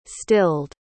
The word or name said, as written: stilled